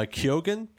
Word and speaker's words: Keoghan: And this is the actor Barry uh, uh, Keoghan, I think is how you pronounce it.